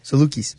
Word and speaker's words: Salukis: And my MVC game, I am taking the Saluskis. Saluskis? How do you say that? – Salukis. – Salukis. I always say it wrong.